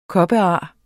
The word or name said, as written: kopar